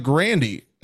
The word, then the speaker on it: Grande: Ariana Grande, as she said in an interview previously, that is how you pronounce her last name, but everyone says Grande. – Are you serious? Her, er, yeah, her name is Ariana, Ariana Grande, but it's Grand, uh, but it's pronounced as her stage name is Grande.